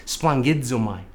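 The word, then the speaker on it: splangizomai (σπλαγχνίζομαι): In the Greek, phonetically, it sounds like this, splangizomai.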